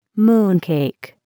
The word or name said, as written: moon cake